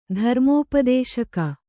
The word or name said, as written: ಧರ್ಮೋಪದೇಶಕ